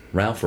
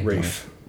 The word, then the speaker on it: Ralph: Ralph or Ray? – Rafe. – Rafe. – Rafe. – Buddy. – It's spelled Ralph, but it's pronounced Rafe.